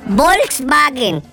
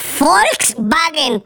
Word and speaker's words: Volkswagen: No se dice Volkswagen. Se dice Volkswagen, babotas.